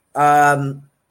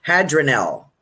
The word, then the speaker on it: Hadraniel: Hadronel. How do you say that? I wish Laura was here. Hadronel. H-A-D-R-A-N-I-E-L. Oops, kind of bright.